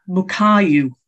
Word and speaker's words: Mukayu: The one I'm going to sort of mention is Mukai, I knew I'd pronounce it wrong. Mukai, it's not even written on the book. Mukayu, it's Japanese.